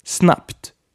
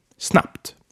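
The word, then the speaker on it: snabbt: Snapped. It's spelled with two B's, but pronounced with a P. Snapped.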